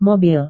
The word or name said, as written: mobil